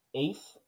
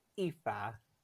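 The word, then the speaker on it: Aoife: It's A-O-I-F-E, and I'm going to say it's Aif or Aif. Yeah, Aif. – That is vomitously bad. That is one of our most common names for women. It's pronounced Aifa.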